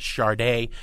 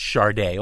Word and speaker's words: Sade: Greg, I think a lot of people think Sade, and it is pronounced Sade, although it's spelled S-A-D-E, and they think the Reagan-Thatcher years.